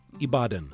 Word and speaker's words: Ibadan: It looked at the health of teens in five cities across the world: Baltimore, New Delhi, India, Ibadan. Am I saying that right? – Yes. – Ibadan.